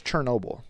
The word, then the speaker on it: Chernobyl: You said I think it's Chernobyl. – Yes. You said it how they say it.